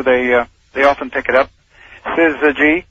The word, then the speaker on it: syzygy: Oh, it sounds like there's an R in there. – Well, it shouldn't. Syzygy.